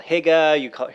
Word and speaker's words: hygge: No, just H-Y-G-G-E. – Okay. So that's how it's spelled. And so you hear it called hygge, hear it called lots of different things. – Yeah, hygge. Yeah, hygge is probably the closest.